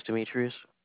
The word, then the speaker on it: Demetrius: My name is Demetrius. – Can you spell that? – D-E-M-E.